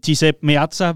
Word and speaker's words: Giuseppe Meazza: Vi åbner denne uges udsendelse med Liverpools europæiske udflugt til Giuseppe Meazza, hvor de første af to opgør mod Inter Milan altså skulle spilles. Var det udtalt rigtigt, Riese? Ja, det tror jeg.